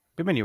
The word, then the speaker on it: Bimini: And the story is actually connected through the submerged rock formation that was found called the Bimini Road, right? Am I saying that right? – Yeah. – Bimini Road, right? – I think so.